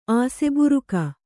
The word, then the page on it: ಆಸೆಬುರಕ: Kannada; āseburaka